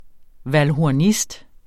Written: valdhornist

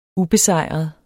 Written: ubesejret

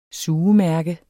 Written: sugemærke

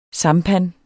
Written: sampan

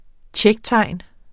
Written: tjektegn

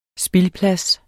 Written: spildplads

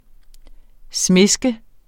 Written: smiske

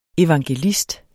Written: evangelist

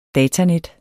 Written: datanet

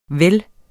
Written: vel

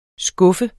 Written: skuffe

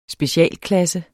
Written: Specialklasse

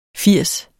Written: firs

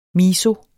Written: MISOEN

